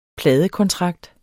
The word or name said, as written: pladekontrakt